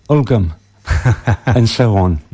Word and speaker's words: Ulgham: It's the old Danish pronunciation, by the way, Uffam.